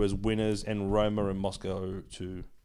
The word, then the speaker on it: Moskva: Is it Moscow? – CSKA Moskva. – Moskva.